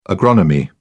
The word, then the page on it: agronomy: British English